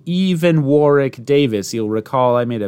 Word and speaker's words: Warwick: I had the pleasure of visiting Warwick Castle on my tour of the UK and Ireland when I was 15, and it's definitely pronounced Warwick.